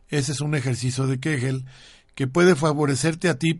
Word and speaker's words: Kegel: Existe una, un, ejercicio muscular tanto en el hombre como en la mujer que se llama ejercicio de Kegel que, eh, o de Kegel, yo lo, pronuncié en alemán, que es Kegel,